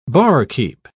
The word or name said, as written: barkeep